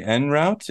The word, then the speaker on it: en route: Oh, how did I pronounce? I said on route. What do you say? En route? And on... You said on route. You said on route. And I was like, I pronounce it wrong. I'm pretty sure I say like en route.